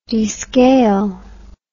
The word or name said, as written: descale